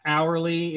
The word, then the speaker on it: hourly: You know how that hourly is kind of like a silent H? Hourly. It's not hourly.